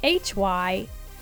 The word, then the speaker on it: Hy-Vee: All right, so it's spelled H-Y-V-E-E.